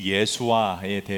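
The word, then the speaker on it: 예슈아: 우리는 지난 5주 동안 기독교의 핵심 이름인 예수와에 대해서 같이 하나님 말씀을 보았습니다. 예수와, 예수님의 오리지널 발음입니다.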